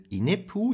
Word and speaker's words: Inepu: Ähm, Band 1 trägt den Titel Inepu. Ich spreche wahrscheinlich alles falsch aus. Nein, du hast es wunderbar ausgesprochen. Es ist genau perfekt.